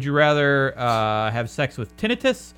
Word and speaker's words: tinnitus: And it is pronounced tinnitus. It's not pronounced tinnitus, no matter what you think. – Well, tinnitus sounds better. – Tinnitus sounds better, but it's pronounced tinnitus.